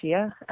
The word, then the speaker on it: sia: And when I came to Sia, technically in Scottish Gaelic it's pronounced Shia, but I go with Sia because there's no H in it.